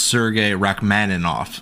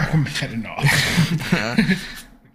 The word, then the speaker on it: Rachmaninoff: Okay, so it's pronounced Sergei Rachmaninoff. – First of all, Rachmaninoff.